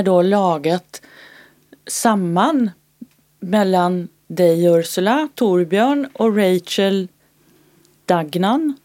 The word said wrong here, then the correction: Dagnall: Är det så man uttalar henne? Dagnall. – Ja. Dagnall. Ja.